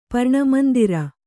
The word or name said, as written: ಪರ್ಣಮಂದಿರ